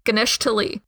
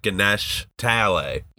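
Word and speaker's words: Ganesh Talai: Like, he knew Ganesh Tali. That's how he said it. It was all one word to him. And instead of it was like Ganesh Tale.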